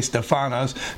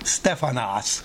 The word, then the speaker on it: stephanos: Emphasis, I always say stephanos, the actual Greek accent is on the first E. It would be stephanos. So I always say it incorrectly. And so we see that in James 1:12 and elsewhere.